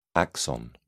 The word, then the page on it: axon: British English